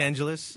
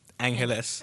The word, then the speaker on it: Angeles: So it's spelled A-N, it's like Los Angeles, mm-hmm. but remove the loss and it's pronounced Angeles.